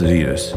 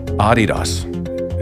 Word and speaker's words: Adidas: It's not pronounced Adidas. – Mm-hmm. – We're the only people in the world that pronounce it Adidas. – Okay. – Adidas. Is how to say it.